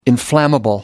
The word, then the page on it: inflammable: American English